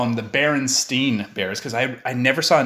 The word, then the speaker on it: Berenstain: You know, I'm comfortable sharing that this whole time up of my life up until now, I've been pronouncing it Bernstein Bears. And it's Berenstain. – Yeah, oh, that's right. The A-I-N.